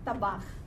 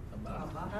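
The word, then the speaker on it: Tabach (טבח): Tabach. – Tabach.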